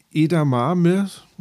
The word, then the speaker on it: Edamame: aber Edamame oder Edamame äh, ist dann tatsächlich auch in verschiedensten äh, Ausspracheweisen äh, äh, irgendwo zu finden. Äh, also aber, ich hoffe, ihr seht es mir nach. Die, dieses Edamame, einigen wir uns einfach mal da drauf.